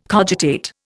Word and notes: cogitate: Indian

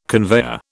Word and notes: conveyor: British English

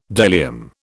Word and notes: bdellium: British English

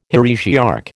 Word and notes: heresiarch: American English

